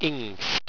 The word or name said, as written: ingst